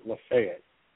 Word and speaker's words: Lafayette: They pronounce it Lafayette. Believe it or not.